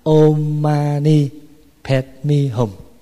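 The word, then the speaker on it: oṃ maṇi padme hūṃ: Là án ma ni bát di hồng, mà đọc theo tiếng Phạn là ôm ma ni pet mi hùng.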